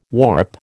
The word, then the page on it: warp: American English